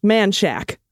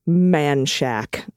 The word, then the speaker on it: Menchaca: And if you're an Austinite like I am, you'll need to know how to properly say menchaca, Road. It's pronounced Man Shack. Okay. – Of course it is. Okay. All right. – Yeah. Yeah. M-E-N-C-H-A-C-A is Man Shack.